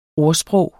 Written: ordsprog

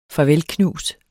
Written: farvelknus

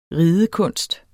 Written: RIDEKUNSTEN

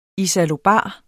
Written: isallobar

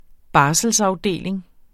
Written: barselsafdeling

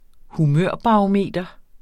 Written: barometer

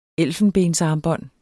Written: elfenbensarmbånd

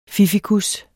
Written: fiffikus

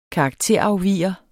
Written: karakterafviger

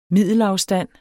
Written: middelafstand

